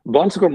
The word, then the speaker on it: Bon Secours: And what do you spend most of your time doing in your current role at Bon Secours Mercy Health, if I pronounced that correctly? – Yeah, you did. Bon Secours Mercy Health, again, is a pretty dynamic organization.